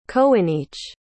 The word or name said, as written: coinneach